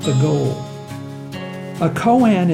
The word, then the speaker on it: koan: Koans are one of the chief cornerstones of Zen Buddhism. K-O-A-N is pronounced Koan.